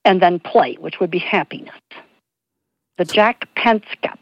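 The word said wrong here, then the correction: Panksepp: That's Panskep.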